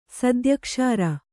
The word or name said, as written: ಸದ್ಯಕ್ಷಾರ